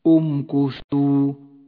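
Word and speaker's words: امكثوا: لا تلفظ همزة الوصل في حالة الوصل وأما في حالة البدء بها فتلفظ وتحرك في هذا الموضع بالضم لأن الحرف الثالث حرف مضموم أمكثوا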